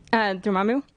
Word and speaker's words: Dormammu: Dormammu.